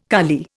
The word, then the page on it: kali: Indian